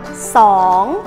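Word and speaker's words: sỏn (สอง): số 1 đọc là nừng Số 2 đọc là sỏn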